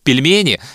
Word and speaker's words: пельмени: You guys keep saying, pronounce it right. Пельмени. Like peel.